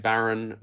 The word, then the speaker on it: Baron: But so my surname, Baron, then the letter I, and then the word fish.